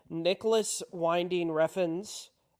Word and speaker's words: Nicholas Winding Refn: I watched Nicholas Winding Refn's.